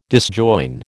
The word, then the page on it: disjoin: American English